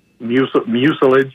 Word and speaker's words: mucilage: And they have a glossy, kind of sticky mucilage, if I'm pronouncing that term properly. – Yes, that's right.